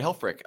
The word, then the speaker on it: Helfrich: Helfrich. – Okay. – The only people who say it absolutely correct are Germans and you know, and I can't do it.